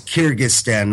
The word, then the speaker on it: Kyrgyzstan: You have six members of the SCO, six full members, five of them Soviet republics, Russia, Kazakhstan, Tajikistan, Kyrgyzstan, and Uzbekistan, and China, and then Afghanistan, India, Iran, Mongolia, Pakistan. – No, it was Kyrgyzstan, not Kurdistan. – Oh, Kyrgyzstan, I said. Yeah, Kyrgyzstan.